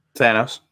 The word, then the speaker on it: Thanos: But something I found interesting here on this Thanos, how do you say it? – Thanos. – All right, Thanos, that's right.